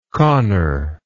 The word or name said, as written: connor